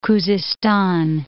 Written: Khuzistan

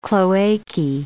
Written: cloacae

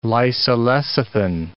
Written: lysolecithin

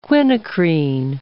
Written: quinacrine

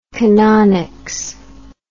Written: canonics

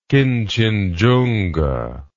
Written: Kinchinjunga

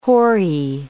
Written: Horae

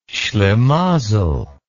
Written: schlimazel

